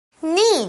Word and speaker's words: knee: Knee 就是膝盖啦，那注意哦，它的拼字虽然它有个 K, but the K is silent.